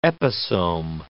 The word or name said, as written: episome